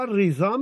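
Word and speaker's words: Rıza: رضا نمیتونه بگه، ای با آ نمیاد، یا ریزا میگه یا ایرزا میگه.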